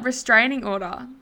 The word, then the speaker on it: restraining order: Straining order. – Restraining order.